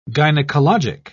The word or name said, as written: gynecologic